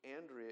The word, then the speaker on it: Andrea: Not dear, dear, tight friends, but Andrea, there we go, that's the Spanish pronunciation, that's Andrea.